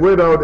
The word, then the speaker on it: widowed: And widowed is nice because it has a sounded and a silent W in it.